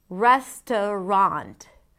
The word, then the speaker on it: Restaurant: Restaurant. Restaurant. Res, the primary stress, t, a schwa, a weak stress, and then rond, a secondary stress.